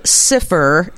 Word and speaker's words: Sifr: It's called Sifr. It's spelled S-I-F-R. And that is an Arabic word for zero, representing a renewed commitment to a fresh start, a new beginning.